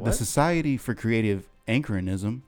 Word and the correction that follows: anachronism: Creative anachronism? – Anachronism. – Okay. – Is that how you say it? – Yes.